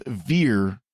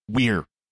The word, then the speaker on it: vir: It's classic Latin pronunciation. So instead of vir for man, it's weir.